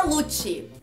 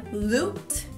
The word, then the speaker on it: loot: E o que, que é lute? Lute não é lutar. Lute, na verdade, a pronúncia correta é loot. Repeat after me loot.